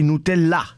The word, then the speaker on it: Nutella: Una frase a Canal Plus si candida per la bufala dell'anno, non bisogna mangiare Nutella, anzi Nutella, con l'accento sulla A, come dice il francese.